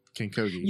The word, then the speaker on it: King Kogi: Your latest project would be King Kogi, right? I'm saying that right? King Kogi.